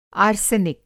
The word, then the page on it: आर्सेनिक: Hindi